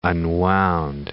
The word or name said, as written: unwound